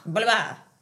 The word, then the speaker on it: Bolívar: Bolívar.